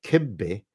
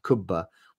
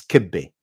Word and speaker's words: kibbeh: I tease my wife, who is Iraqi, that kibbeh is the proper way to pronounce the dish, not kubba, which is the Iraqi pronunciation.